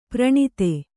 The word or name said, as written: ಪೌರನೀತಿ